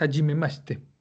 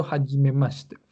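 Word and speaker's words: hajime maste (はじめまして): En japonés, eh, hajime maste, es hajime maste. Aunque lo quieras decir rápido, sigue siendo hajime maste.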